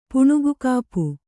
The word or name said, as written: ಪುಣುಗುಕಾಪು